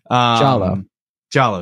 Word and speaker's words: Giallo: Giallo.